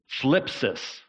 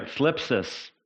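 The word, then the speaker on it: thlipsis: Now, this word suffering is the word phlipsis. Everybody say that. If you feel like you have a lisp, you're saying it right. Phlipsis.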